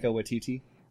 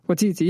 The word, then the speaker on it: Waititi: And Thor Ragnarok's another great example, because you talk about, and I'm never sure how to pronounce his name, but Waititi, is that how you say his name? – Yeah. Taika Waititi. – Waititi, yeah.